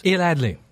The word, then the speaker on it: Adly: Correct pronunciation is? – Adly. – Adly.